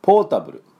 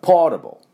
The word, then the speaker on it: ポータブル: まあ、あとはですね、ではそれに関連しまして、ポータブル、ポータブルもですね、よく出てきますよね。日本語英語になってますね。これは正しい発音でいきたいと思います。これポイントはですね、ポーって伸ばすのは多分問題ないと思いますが、ポータブルのタのところが半母音ですから、出しても出さなくてもいい、ちょっとあっていうだけです。だからポータブル、ポータブルではありません。